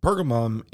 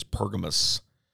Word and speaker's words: Pergamon: Pergamon is the proper pronunciation of what we see in the King James as Pergamos.